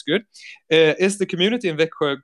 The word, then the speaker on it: Växjö: Okay, Daniel is representing Växjö.